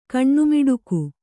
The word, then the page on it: ಕಣ್ಣುಮಿಡುಕು: kaṇṇumiḍuku